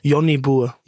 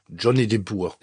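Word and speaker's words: Jonnie Boer: Und dann den spricht man nicht so aus. – Sag mal, wie man es ausspricht. – Johnny Burr. – Johnny Burr, sehr gut.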